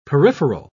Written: peripheral

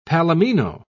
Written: palomino

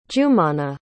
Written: jumanah

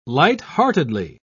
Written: lightheartedly

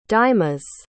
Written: dymas

